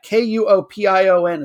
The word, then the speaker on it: Kuopio: The town is spelled KUOPION. It's pronounced Kuopio.